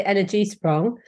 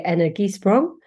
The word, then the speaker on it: Energiesprong: I say Energiesprong, but our Dutch colleagues say Energiesprong.